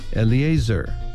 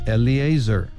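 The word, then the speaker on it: Eleazar: 퍼네이하스 이렇게 발음하시고 그 다음 엘르아살은 엘리에이저 한번 더요. 엘리에이저 이렇게 연습해 주시기 바랍니다.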